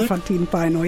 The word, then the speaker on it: Elefantin paino: Der finnische Popstar Elinora mit Elefantin Paino, habe ich das richtig gesagt? – Elefantin Paino, ja.